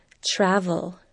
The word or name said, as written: travel